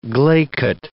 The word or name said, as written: glaikit